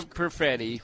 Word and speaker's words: Perfetti: And Andrew Perfetti rhymes with confetti.